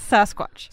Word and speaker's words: Sasquatch: It's Sasquatch. – Sasquatch. – It's like hard on the first syllable.